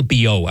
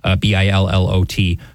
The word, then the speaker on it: Billot: Cardinal Billot, I think, is how you would pronounce it in French. Billot, who helped draft the.